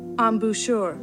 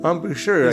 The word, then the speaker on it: embouchure: Embouchure. – Embouchure, yeah. – Embouchure, right. His lip, right? Mm-hmm. And so he couldn't play for a while, but he finally got dentures and retrained his embouchure.